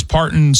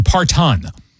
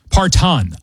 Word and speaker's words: Parton: Might I point out that Miss Parton's surname does include a T with the emphasis on the second syllable, the pronunciation being Parton. Parton. Each time it was used in the report, your morning personality, or Chelsea, your morning personality omitted the T, emphasizing the second syllable, saying Parton.